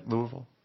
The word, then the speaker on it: Louisville: You are a Louisville. Did I pronounce that right? Louisville.